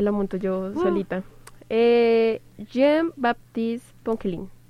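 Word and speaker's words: Poquelin: Se dice Jean-Baptiste Ponquelin.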